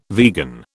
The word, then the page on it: vegan: British English